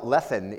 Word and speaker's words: lambano: So lethen is the way you pronounce it there.